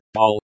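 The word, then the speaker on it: боль: Pronounced bol, as in bowl, Russian.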